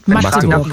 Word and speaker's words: Magdeburg: Wie wird man richtig ausgesprochen? – Magdeburg. – Magdeburg. – Magdeburg. Ah, okay. Einfach ein kurzes mhm. A.